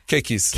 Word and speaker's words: keikis: Is it Kiki for children? – Keikis. – Keikis.